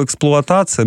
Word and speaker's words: эксплуатация: Ведь если, например, взять слово «эксплуатация», мы же его так произносим, а в 48-м году нас бы сочли неучим. «Эксплуатация» через «о», например.